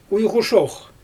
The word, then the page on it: ujɣu ʃox: Xyzyl